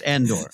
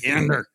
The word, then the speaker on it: Andor: Like, we're already not pronouncing the names correctly. Like, he's going to be, it's Andor. Andor.